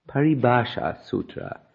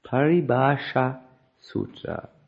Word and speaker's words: Paribhasha Sutra: Prabhupada has, as I said, there is this Paribhasita Sutra. Can you please say it correctly? I may not say it correctly. Paribhasha Sutra. Paribhasha Sutra.